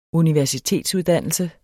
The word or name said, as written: universitetsuddannelse